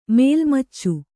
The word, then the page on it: ಮೇಲ್ಮಚ್ಚು: mēlmaccu; Kannada